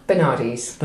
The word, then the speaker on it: Bernardes: How do I say your surname, Eleanor? – Bernardes. – Bernardes. Yeah. Okay, I never use it.